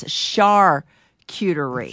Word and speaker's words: Charcuterie: Even though it's spelt with a C, you pronounce it with an S. Charcuterie.